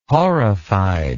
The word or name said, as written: horrified